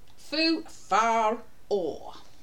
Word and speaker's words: foofaraw: It is spelled F O O F A R A W. Foo far or.